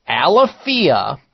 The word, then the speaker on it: Alaffia: Aleafia, it's spelled A-L-A-F-F-I-A, Aleafia Coconut Reishi Activated Deodorant.